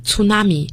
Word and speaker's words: tsunami: 那么还有一个在 2004 年的 12 月份我们有一场非常大的灾难叫海啸 tsunami, T-S-U-N-A-M-I。要注意不要读成 tsunami, 要读成 tsunami。